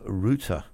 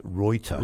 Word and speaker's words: Reuter: There was another fellow that was born on this day, and his name was Paul von Ruter. – Never heard of him. A Yiddish Dutchman. – Actually, Ruter is not the right pronunciation. It's Reuter.